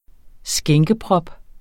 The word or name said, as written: skænkeprop